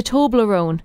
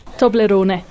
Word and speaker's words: Toblerone: The Toblerone. That's not how you pronounce it, guys. – Oh. Toblerone? – Toblerone? – Go on, change our lives forever. – Toblerone.